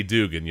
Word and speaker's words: Duggan: It's D-U-G-G-A-N. But here in the South, we say Dugan, y'all. Not Duggan. It's not Hacksaw Jim Duggan. – Oh. – Oh. I love that dude, man. But Max Dugan, that guy, the hell of a job.